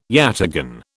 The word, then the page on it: yataghan: British English